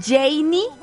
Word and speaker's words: Jeinny: Y por cierto, me puedes seguir en redes sociales arroba Radio Disney MX, arroba Janie Capistrán. Mi nombre se escribe así, J-E-I-N-N-Y.